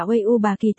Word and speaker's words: V587: Đúng là đủ loại sở thích, V587 đọc là uy u bà kỳ tức là uy vũ khí phách.